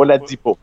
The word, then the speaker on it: Oladipo: Hier, j'écoutais l'émission Alley-Hoop 360 au 91.9 et on posait la question, est-ce que Victor Oladipo... j'ai de la misère à le prononcer. Tu vois le mot, Oladipo.